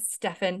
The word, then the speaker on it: Steffen: I am here with Dr. David Steffen.